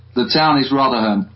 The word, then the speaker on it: Rotherham: Rotherham. – Spell that. – R-O-T-H-E-R-H-A-M.